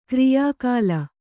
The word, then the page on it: ಕ್ರಿಯಾಕಾಲ: kriyā kāla